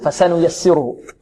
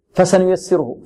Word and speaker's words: فسنيسره: ربما البعض يقول فسنيسره فسنيسره فسنيسره لا فسنيسره فسنيسره نعطي الفاء نعد الفتحة التي على الفاء